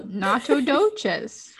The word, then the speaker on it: Nacogdoches: Nacho doset. No, nacho doches. Nacho doches.